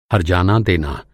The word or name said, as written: हरजाना